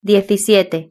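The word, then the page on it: diecisiete: Spanish (Spain)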